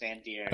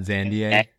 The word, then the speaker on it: Zandier: And then we have Zane Zandier, who I learned this week is pronounced Zandier. – Oh, it's not Zandier? – Yeah. Typical UVA French pronunciation.